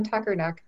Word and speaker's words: Tuckernuck: This is a wonderful opportunity for a summer read conversation because Francine Matthews' new book, Tethet Tupperknuck, do I have that right? I can't pronounce it. – Death on Tuckernuck.